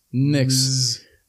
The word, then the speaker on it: MX: How do you say that? – Mix.